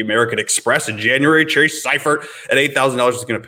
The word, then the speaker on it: Seifert: His name is Seifert. It is S-E-I, but it is pronounced S-Y, like sci-fi for Seifert.